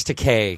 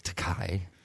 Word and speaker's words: Takei: Oh, excuse me, George Takei, because it rhymes with gay. Right. Even though I grew up with it was Takei.